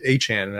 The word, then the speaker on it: Achane: Devon Achane. – Achane. That's how you pronounce it.